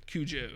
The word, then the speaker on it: Cujo: Cujo, yes.